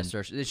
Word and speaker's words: Saoirse: Yeah, Saoirse. She says the way to remember it is it's pronounced like inertia.